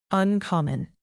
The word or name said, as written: uncommon